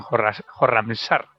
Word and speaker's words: Khorramshahr: y el primer obstáculo se lo encontró en la ciudad de Khorasán, en Khorramshahr, voy a pronunciarlo bien.